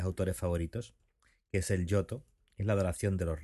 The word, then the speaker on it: Giotto: Yoto, G-I-O-T-T-O, con dos T's.